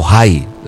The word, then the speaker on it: Ai: Iban a tomarse a Ai, o Jai, le llaman, porque la pronunciación de la H es más con la J, según el hebreo.